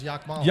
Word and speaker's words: Yakmala: Okay, Yakmala. Cool. – Does he say it like that? – He does. Yep. – Does the mullet do it? – The entire town says Yakmala. – Yakmala.